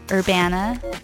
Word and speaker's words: Urbana: I've pronounced the city name wrong numerous times, but it's Urbana.